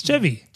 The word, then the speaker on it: Chevy: Chauvin. – Chevy. – It's Chevy.